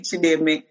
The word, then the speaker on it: Chideme: It's like the D is a the. It's like a Deme, Chideme.